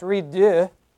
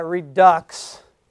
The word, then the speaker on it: redux: which is Enjoying the Process Redux. Most of you are pronouncing that redux. No, it's redux.